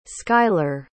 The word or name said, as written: skyler